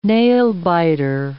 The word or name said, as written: nail-biter